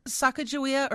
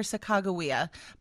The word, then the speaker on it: Sacagawea: One of whom, I don't think they encountered her, but they brought her for the journey, was Sacagawea. How do you say it? – Sacagawea or Sacagawea. Both are acceptable pronunciations of her name.